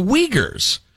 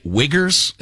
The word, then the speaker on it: Uyghurs: And he was talking about the Uyghurs, although he kept pronouncing it Wiggers, and it really made me uncomfortable.